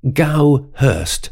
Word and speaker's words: Goudhurst: so I thought it was, is it Goudhurst or Goudhurst? So I looked it up, and it's Gowhurst, with D in the middle of the name, silent.